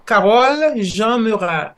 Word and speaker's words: Carolle Jean-Murat: Uh, it's Dr. Carolle Jean Murat.